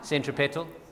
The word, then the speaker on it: centripetal: I don't know how to pronounce it properly. – Centripetal? – Centripetal.